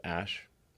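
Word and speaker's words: Æ: So, I mean, it's just X, the letter X, and then the AE is like pronounced Ash.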